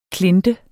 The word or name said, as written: klinte